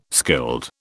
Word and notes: skilled: British English

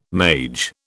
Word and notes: mage: British English

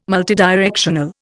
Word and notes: multidirectional: Indian

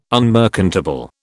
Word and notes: unmerchantable: British English

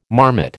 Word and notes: marmot: American English

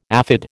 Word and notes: aphid: American English

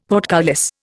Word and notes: portcullis: Indian

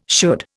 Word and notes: should: Indian

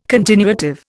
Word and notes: continuative: Indian

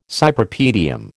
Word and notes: Cypripedium: American English

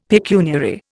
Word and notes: pecuniary: Indian

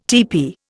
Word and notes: tepee: Indian

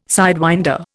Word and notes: sidewinder: Indian

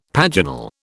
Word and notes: paginal: British English

